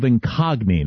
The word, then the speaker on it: Incogni: They can't scam you if they can't find you with the help of Incogni. Now, it's a lot like the word incognito. It's just shorter. It's spelled I-N-C-O-G-N-I, Incogni.